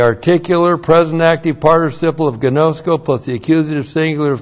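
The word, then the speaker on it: Gnosko (γινώσκω): Our old friend gnosko. G-I-N long O-S-K long O. Gnosko.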